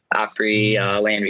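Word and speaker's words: Opryland: Opry Land Resort. So I think I said that right? – the Grand Old Opry. Yeah. Yep.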